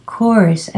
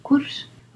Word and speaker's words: course: And of course, the very famously mispronounced word in Spain, course, a diving course, un curso de buceo, a diving course, course, an English course, it's not curso, okay?